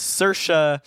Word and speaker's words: Saoirse: She said, we're watching a movie and she said, is that Cersei Roman's husband? – Damn. – And I was like, well, first of all, it's Saoirse Ronan, but also, can you blame her? – I mean, no.